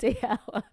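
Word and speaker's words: Dalziel: So you know that Scottish name that's D-A-L-Z-I-E-L. Ada, write in. Is it pronounced D.L.? I think it is.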